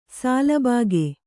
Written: ಸಾಲುಬಾಗೆ

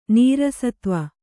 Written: ನೀರಸತ್ವ

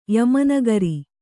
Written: ಯಮನಗರಿ

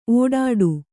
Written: ಒಡ್ಡು

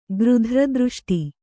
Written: ಗೃಧ್ರದೃಷ್ಟಿ

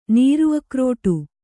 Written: ನೀರುಅಕ್ರೋಟು

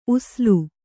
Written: ಉಸುಲು

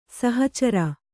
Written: ಸಹಚರ

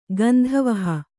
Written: ಗಂಧವಹೆ

